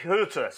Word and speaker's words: Hütter: How do you pronounce Hütter? Over to our German expert. – Ralf Hütter. – It has an umlaut over the U, so that...